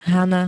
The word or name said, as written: Hana